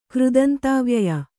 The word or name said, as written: ಕೃದಂತಾವ್ಯಯ